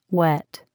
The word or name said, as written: wet